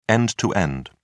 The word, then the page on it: end-to-end: British English